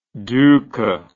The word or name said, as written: duka